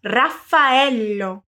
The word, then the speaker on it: Raffaello: O nome do Rafa já nos traz duas consoantes duplas diferentes. Então, vamos prestar atenção nelas e pronunciá-las mais demoradamente. Mas vamos por partes. Primeiro, quero que você fale para mim só Rafa. Rafa. Agora só o ello. Ello. Agora sim, tudo junto. Raffaello.